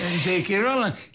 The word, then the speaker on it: J.K. Rowling: Per esempio è uscito, è stato pubblicato il primo di una serie di romanzi fantasy scritti da J.K. Rowling, l'ho detto bene? – Sì, beh. J.K. Rowling